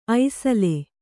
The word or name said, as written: ಐಸಲೆ